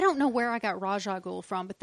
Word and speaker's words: Ra's al Ghul: Not just between us, but it is Ra's al Ghul.